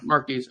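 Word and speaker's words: Marques: Marquez.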